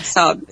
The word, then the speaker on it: SOBS: SOBS é assim que pronuncia? Isso, SOBS.